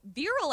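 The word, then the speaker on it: virile: No, viral, I think, is V-I-R-I-L, like virility.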